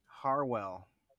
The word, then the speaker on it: Harwell: What about Steve Harrell? What about Steve Harrell? How you say his name? – Harwell. – Harwell? – Yeah.